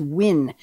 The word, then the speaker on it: Nguyen: And it's not N-G-U-Y-E-N. It's Win. So it's W-I-N phonetically.